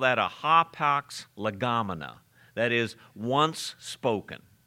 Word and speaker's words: hapax: Once, the word once, you pronounce it hapax, hapax, H-A-P-A-X, hapax, hapex.